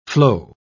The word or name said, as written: flow